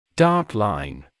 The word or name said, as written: dark line